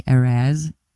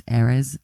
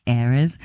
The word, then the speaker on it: Erez: They always say, you know, Americans say Erez, but it's Erez actually, Erez.